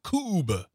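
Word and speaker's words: Kubb: It is K-U-B-B. K-U-B as in boy, B as in boy. Cube. – Yep. Rhymes with tube.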